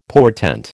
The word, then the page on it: portent: American English